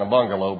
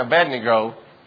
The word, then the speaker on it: Abednego: And I used to mispronounce that when I was young. I used to say my shack, your shack, and a bungalow. But anyway, Shadrach, Meshach, and Abednego danced with him in the fiery furnace.